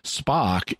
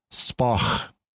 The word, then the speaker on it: Spock: Spock is just the English pronunciation of his name. Really, it's Spock, with the guttural sound, which we don't use in standard English, although it is in Scottish English.